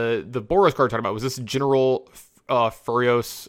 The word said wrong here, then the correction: Ferrous: or Furios Rokiric? – Ferrous Rokiric, yeah.